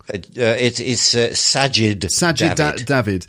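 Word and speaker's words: Sajid Javid: I feel like Savid... I always get his name wrong. – Sajid David.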